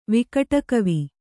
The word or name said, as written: ವಿಕಟಕವಿ